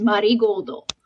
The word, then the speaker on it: marigoldo: So it's Marigold for like our Western palette, but the pronunciation they use is the Japanese pronunciation, which is marigoldo.